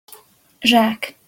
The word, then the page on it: zsak: Hungarian